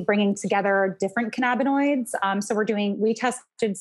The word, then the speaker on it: cannabinoids: Is THC like the important factor in when you guys are like synthesizing and doing these tests on, I can't even know if I pronounced it, cannabinoids? – Cannabinoids, you're killing it.